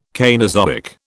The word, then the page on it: Cainozoic: British English